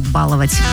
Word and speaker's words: баловать: Ударение в слове «баловать» в будущем изменится и будет падать только на первый слог, сообщили в Институте русского языка имени Виноградова.